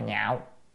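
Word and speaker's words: nhạo (樂): A Nan thường nhạo đa văn đó, tức là ngày A Nan á thì thường thích đa văn, chữ này là chữ nhạo, đọc là nhạo, nó có ba âm. Một âm là lạc, một âm là nhạc, một âm là nhạo, nhưng mà lạc á, thì là vui thì không đúng à. Mà nhạo á, là mới ưa thích, còn nếu âm nhạc á, thì thành nhạc của âm nhạc mất tiêu, danh từ không đúng, cho nên phải âm đọc là nhạo.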